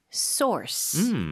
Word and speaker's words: source: 이거는 source. 중간에 R 발음 강하게 해주셔야 돼요.